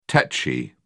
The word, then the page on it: techy: British English